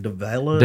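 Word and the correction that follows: Daveigh: DeVay Chase. – DeVay Chase, mm-hmm.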